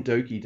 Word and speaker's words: Douki: I've taken to spelling Doki, not D-O-U-K-I, how it's spelled, but D-O-K-I, just so I don't keep driving Dave Howard fucking insane.